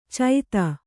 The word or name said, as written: ಚೈತ